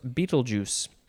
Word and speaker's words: Betelgeuse: This is a Portuguese word, betel, I'm not going to pronounce it, B-E-T-E-L-E, from a Tamil word, virilai. Next we have, Betelgeuse, capital B-E-T-E-L-G-E-U-S-E. And the pronunciation guide does say it is pronounced Betelgeuse. Or Betelgeuse, or Betelgeuse.